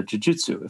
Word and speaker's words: Jiu Jitsu: How and is it and how or is it different from Japanese uh, Jiu Jitsu, if I'm pronouncing that right? – Yes, totally.